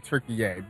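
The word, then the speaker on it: Türkiye: I believe it's actually Turkey-ay, but we grew up with it as Turkey.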